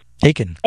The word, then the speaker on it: Achan: Achan.